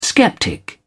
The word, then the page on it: skeptic: British English